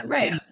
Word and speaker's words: Rao's: I've always said Rao's. I'm probably saying it wrong. Is it Rao's? It's just, there's a restaurant in New York City. – Rao's.